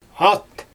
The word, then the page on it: at: Xyzyl